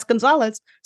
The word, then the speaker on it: Gonzalez: And that's Gonzalez spelled with 2 Z's.